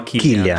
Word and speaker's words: Cillian: Comunque si chiama Killian. Killian, scusate, eh, Killian.